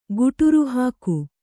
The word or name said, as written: ಗುಟುರುಹಾಕು